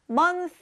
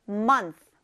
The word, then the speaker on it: month: 자, month에 뒤에 th 발음이 있으니까 month 하면서 이 혀를 윗니와 아랫니 사이에 넣고 th 발음해주는 거 잊지 마세요. 자, 그럼 따라해보세요. month, month, month.